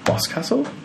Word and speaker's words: Boscastle: at the Museum of Witchcraft conference in Boscastle.